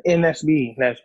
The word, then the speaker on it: NSBE: Yeah, NSBE, yes.